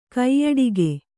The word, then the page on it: ಕಾಯಡಕೆ: kāyaḍake